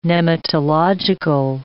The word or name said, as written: nematological